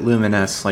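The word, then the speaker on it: Lumines: I pronounce it Luminous, like Illuminate.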